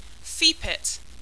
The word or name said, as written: feepit